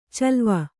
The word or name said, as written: ಚಲುವೆ